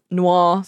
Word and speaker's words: noir: The 1989 neo-noir thriller film. And it's got Al Pacino. – Noir. – Sorry. I'm far yeah. too Australian to pronounce that correctly.